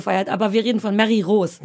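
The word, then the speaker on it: Mary Roos: Nee, die andere Frau, die neulich auch bei Sing Mein Song war, die Mary Rose. – Ja, Mary Rose. Oh. – Man darf das nicht englisch aussprechen. Mary muss man sagen, ne? – Ja, aber wie wenn ich Rose.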